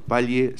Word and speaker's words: Palle: no sé si Palle se pronuncia así. Esta es la gran duda, Andrea, que tenemos. ¿Cómo estás? Buen día, bienvenida. – Buenos días, ¿cómo le va? Palle, así se pronuncia.